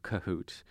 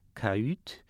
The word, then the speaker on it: cahute: C-A-H-U-T-E. So in French, that would be pronounced something like cahoot.